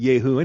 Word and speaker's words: Jehu: Well, God's doing away with them, and Jehu is a Yehu. who, and probably a Hebrew should be pronouncing it Yehu anyway, but he's a villain.